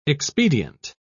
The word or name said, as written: expedient